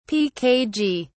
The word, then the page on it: pkg: British English